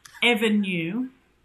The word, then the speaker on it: Evrnu: Um, and then another company called Evrnu, I don't know how to pronounce that, Evrnu. Oh, Evrnu. Oh, I get it now. Evrnu.